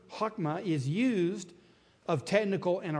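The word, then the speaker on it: chokmah (חכמה): The word wisdom, and once again, I put it there for you in Hebrew, and if you pronounce that, that would be chokmah. That H is a hard H, chokmah.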